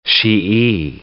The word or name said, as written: Shi'i